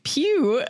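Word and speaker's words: Pugh: Then we got Florence Pug played Poog Puh. I don't know how you say it. – Florence Pew.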